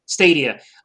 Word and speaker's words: Stadia: My friend gifted me a Stata. Is that how you pronounce it? – Stadia.